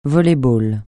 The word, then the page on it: volley-ball: francais